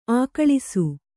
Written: ಅಗಳಿಸು

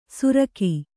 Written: ಸುರಕಿ

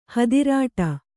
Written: ಹದಿರಾಟ

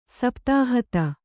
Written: ಸಪ್ತಾಹುತಿ

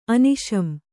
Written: ಅನಿಶಂ

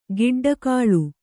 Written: ಗೊಡ್ಡಾಕಳು

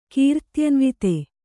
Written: ಕೀರ್ತ್ಯನ್ವಿತೆ

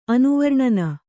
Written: ಅನುವರ್ಣನ

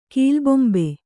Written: ಕೀಲುಬೊಂಬೆ

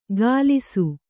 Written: ಕಲಿಸು

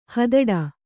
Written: ಹಿದೌಠ

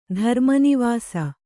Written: ಧರ್ಮನಿವಾಸ

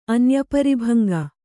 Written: ಅನ್ಯಪರಿಭಂಗ

